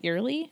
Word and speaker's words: Earley: I don't know how to pronounce it. E-A-R-L-E-Y. Early? – Yeah.